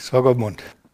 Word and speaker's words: Swakopmund: Swakopmund, äh, Namibia, spricht man das so? Richtig, Swakopmund.